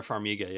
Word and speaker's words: Farmiga: Vera Farmiga, yep.